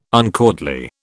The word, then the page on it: uncourtly: British English